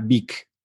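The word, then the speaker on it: Beek: Consumo en Script, eh, consumo en Storytel y estoy probando una nueva que se llama Vic también, que es mexicana. se escribe B-E-E-K y estuvo en Y Combinator, es mexicana y está haciendo cosas padres.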